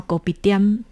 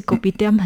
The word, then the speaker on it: Kopitiam: 因为他们用的是闽南语的发音，所以南洋的茶室叫 Kopitiam，其实 Kopitiam 很就是我们的。